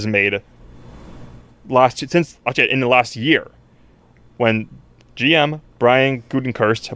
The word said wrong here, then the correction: Gutekunst: Not even very close. – All right, how do you say it? – Gutekunst. – Oh, totally different. Gutekunst. – There's no R.